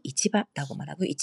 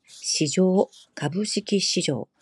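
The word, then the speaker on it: 이치바라고 말하고 (市場): 이치바라고 발음하고요 똑같은 한자지만 주식시장 시조, 가부시키 시조, 가부시키 시조 이렇게 어, 읽습니다. 같은 한자라도 시조하고 이치바 두 가지 읽는 방법이 있다는 점 기억 꼭 하시고요.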